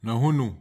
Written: nahunu

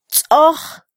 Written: tzoj

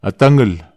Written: atagel

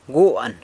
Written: guan

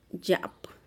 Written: zha'ap

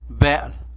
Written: bel

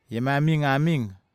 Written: iamamigamig

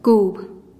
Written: kub

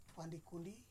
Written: Pandikundi

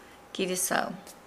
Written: gili-sau

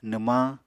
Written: nwmañ

